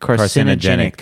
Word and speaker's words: carcinogenic: Classified processed meat, for example, meat that has undergone salting, curing, fermenting, and smoking are carcinogenic to humans. I didn't say that right. – That's carcinogenic. – Carcinogenic. – That's as hard. That is hard. – Carcinogenic. I miss a syllable.